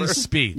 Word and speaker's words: Spieth: It's Spieth, with T-H.